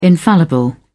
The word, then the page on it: infallible: British English